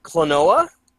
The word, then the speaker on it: Klonoa: It's spelled Kelonoa. – Klonoa? – Yes.